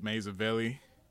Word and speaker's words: Mazavelli: You can find me on Instagram at Mazavelli. That's spelled exactly how it sounds.